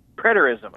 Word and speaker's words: Preterism: Preterism, okay.